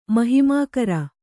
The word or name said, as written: ಮಹಿಮಾಕರ